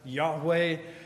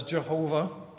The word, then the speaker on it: Yahweh: It's spelled in Hebrew, Yod-Heh-Vav-Heh, pronounced by some as Yahweh, or rendered famously in the King James Bible as Jehovah, right?